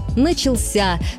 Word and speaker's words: начался: Но по нормам русского языка правильный только один – «начался» с ударением на последнем слоге.